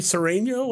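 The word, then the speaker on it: Sureño: You were an honorary Sereno, or did I say that right? – Yeah, yeah.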